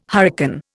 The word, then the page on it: hurricane: Indian